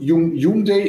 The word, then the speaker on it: Hyundai: Wie, was, was ist denn die richtige Aussprache? Jung Day. Jung, Jung Day ist die korrekte Aussprache, genau.